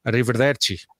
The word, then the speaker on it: arrivederci: If not, we'll just close it out with a couple more items, and we'll say arrivederci.